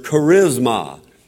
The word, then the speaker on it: charisma: Now the word there for gifts is the word charisma. And it's spelled C-H-A-R-I-S-M-A, charisma.